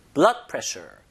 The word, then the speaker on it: blood pressure: 그래서 두 단어를 그냥 붙여서 발음하는 법만 자, 두 개의 단어가 이렇게 합성돼서 쓰일 때 강세, 즉 힘을 앞에다 줍니다. blood pressure, blood pressure. 읽어보세요. 들어볼게요. – blood pressure, blood pressure.